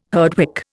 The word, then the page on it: Herdwick: Indian